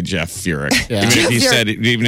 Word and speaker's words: Furyk: No, Jim Furyk. It's Furyk is the name.